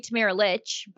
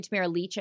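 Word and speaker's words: Tamara Lich: But if you were wearing a shirt that said Free Tamara Leach, by the way, or Free Tamara Leach, I should start saying her name properly.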